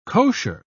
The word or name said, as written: kosher